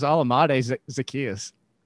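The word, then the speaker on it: Olamide Zaccheaus: It's not that hard. It's Alamade Zacchaeus.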